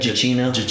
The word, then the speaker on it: Giacchino: Oh, Michael Giacchino, is that how you say it? – Giacchino. Yeah, he was fantastic.